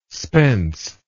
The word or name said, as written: spence